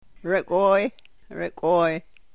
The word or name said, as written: rekwoy